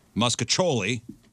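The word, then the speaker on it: Mostaccioli: Muscacholi is how you pronounce it.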